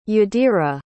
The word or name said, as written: jadira